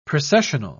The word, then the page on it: precessional: British English